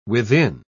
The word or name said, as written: within